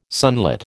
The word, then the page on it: sunlit: American English